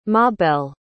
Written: mabell

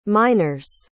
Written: miners

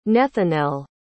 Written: Nethanel

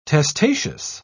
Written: testaceous

